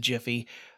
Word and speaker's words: Giphy: has been investigating Facebook's acquisition of Giphy, or if you prefer, Jiffy. I'm sure they pronounce it Jiffy.